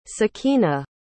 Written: sakina

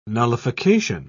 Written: nullification

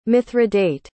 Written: mithridate

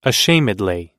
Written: ashamedly

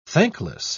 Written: thankless

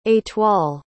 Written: etoile

